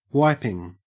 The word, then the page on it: wiping: Australian English